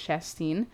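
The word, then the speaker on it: Chastain: I keep saying her name wrong, but it's Shanstein.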